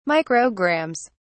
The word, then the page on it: micrograms: British English